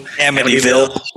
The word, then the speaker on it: Amityville: So were they the ones that wrote like Amityville? How do you say it? – Amityville.